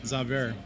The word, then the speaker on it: Zauber: Which is fitting because Zauber. Zabir. Wait, Zauber. – I keep pronouncing it wrong. Zauber is German for magic.